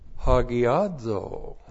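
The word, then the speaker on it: hagiazō: H-A-G-I long O-S-U-N long E. Hagiosune. Hagiosune is the noun that comes from the verb hagiadzo. That's the noun from this verb, hagiadzo. H-A-G-I-A-Z long O. Hagiadzo.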